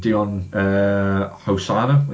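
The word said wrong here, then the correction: Djimon Hounsou: Daimon Honsu.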